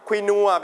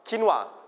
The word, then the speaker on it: quinoa: อันนี้เราเรียกว่าสมุนไพรภาษาอังกฤษเรียกว่าอะไรสมุนไพรอ่านได้สองแบบอ่านว่า herbs โอเค everybody herbs ถ้าเป็นอเมริกันจะไม่ออกเสียงตัว h เหมือนคำว่า hour ที่แปลว่าชั่วโมงอ่านว่า herbs herbs นะสุดท้ายอันนี้น่าจะเป็นคำศัพท์ใหม่สำหรับเด็กๆนะครับผมอันนี้ออกเสียงว่า quinoa แบบอังกฤษหรือ quinoa quin quin แล้วก็ wa w a wa quinoa quinoa